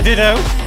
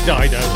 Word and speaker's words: Dido: A bit of ditto. I'm intentionally saying that wrong, so don't, like, listeners, don't get annoyed at me. It's like people who say GIF, not GIF. You're wrong! I know it's Dido.